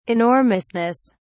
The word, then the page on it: enormousness: British English